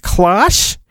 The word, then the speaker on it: Klosh: And the sound effect they have here is I'm going to pronounce it Klosh. K-L-O-S-H. Klosh!